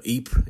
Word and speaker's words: Ypres: So Ypres, it's actually pronounced the same in both, but it looks very, very different. I-E Y-P-E-R is the Flemish or Dutch version. Y-P-R-E-S is the French version, and that was the version that was being used during the First World War.